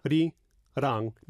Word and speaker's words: Arirang: So you can almost take them one at a time. – Yeah. – A-ri-rang. Then just put them together. – Yeah. – Arirang. – Yes. – So you don't say Ari. – No. – A-ri. – Yeah. – It's not Arirang. It's not Arirang. It's Arirang. Arirang.